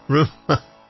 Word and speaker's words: Rumer: Rumour, or Rumma, I like to call her Rumma because it's spelled R-U-M-E-R, but Rumma.